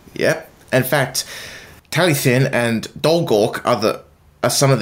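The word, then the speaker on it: Talyllyn: In fact, did I pronounce it right? – Talythin, you did.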